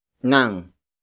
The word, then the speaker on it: ng: And then we have nang. Nang. Nang. Nang is spelled as ng. It is the direct object marker, indicating that the next word is what I am reading.